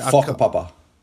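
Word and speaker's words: whakapapa: Is it Waka Thaka or Waka? – Faka Papa. – Fuck up, Papa. Although it's sort of spelt W, but is that, I'm guessing that's the way it's pronounced. – It's an F, silent, so yeah, it's F, yeah.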